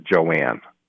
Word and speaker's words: Joan: Well, it's spelled like Joan. It's spelled J-O-A-N, but she pronounces it Joanne, her family.